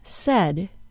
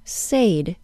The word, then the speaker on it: said: According to its spelling, the word said should be pronounced as said. It should rhyme with the word paid, but it doesn't. Instead, the word said is pronounced with a short E sound and rhymes with the word bed, as well as the word instead.